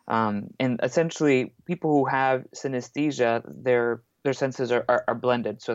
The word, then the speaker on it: synesthesia: There's actually an A in the middle of the word, so you mispronounce it like synesthesia. – Yeah. – But it's synesthesia, like anesthesia, but uh-huh.